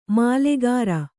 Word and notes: ಮಾಲೆಕಾರ: mālekāra

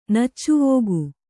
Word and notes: ನಚ್ಚುವೋಗು: Kannada

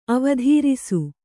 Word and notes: ಅವಧಾರಿಸು: avadhārisu; Kannada

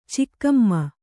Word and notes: ಚಿಕ್ಕಮ್ಮ: cakkamma

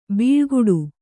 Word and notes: ಬಾಳುಗೇಡಿ: bāḷugēḍi

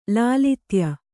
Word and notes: ಲಾಲಿತ್ಯ: Kannada

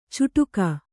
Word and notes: ಚುಟುಕ: cuṭuka

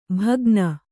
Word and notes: ಭಗ್ನ: bhagna

